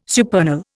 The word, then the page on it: supernal: Indian